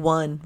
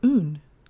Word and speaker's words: un: So his name is one, and in French it's pronounced un.